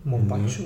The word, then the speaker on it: Mombacho: Mombacho, mhm.